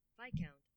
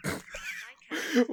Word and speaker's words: Viscount: Viscount. Wait, the British ones are putting more emphasis on the I? – On the count, apparently.